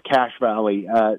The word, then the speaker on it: Cache Valley: It's pronounced Cache Valley. The word cache in it, it's spelled different.